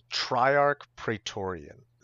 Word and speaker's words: Triarch Praetorian: laughs> So the most interesting black commander, I think, is called Triarch Praetorian.